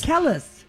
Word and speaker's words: Kelis: And how do you say her name? Singer Kelis? – Kelis. – Kelis. Like we should all know.